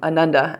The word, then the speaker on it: Ananda: And this word, I think I've done this once before, but the word Ananda means bliss, and it's spelled A N A N D A and the A's are pronounced Ananda.